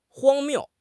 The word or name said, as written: 荒谬